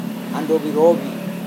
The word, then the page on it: andowi-rovi: Serewen